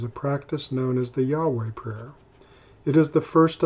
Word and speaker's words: Yahweh: The name of God, as revealed to Moses in Exodus 3:14, is YHWH, or what we pronounce as Yahweh.